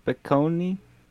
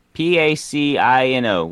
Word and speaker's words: Pacino: Names like James Caan, Jack Nicholson, Robert De Niro, Al Piccone? Piccone? I know the name. I just can't pronounce it. Al... Pacino.